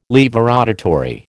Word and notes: laevorotatory: American English

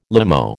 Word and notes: limo: American English

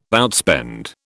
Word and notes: outspend: British English

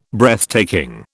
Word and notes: Breathtaking: British English

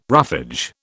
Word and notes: roughage: British English

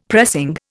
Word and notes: pressing: Indian

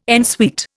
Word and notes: en suite: Indian

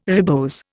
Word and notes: ribose: Indian

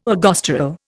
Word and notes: ergosterol: Indian

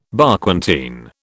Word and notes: Barquentine: British English

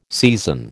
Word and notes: season: American English

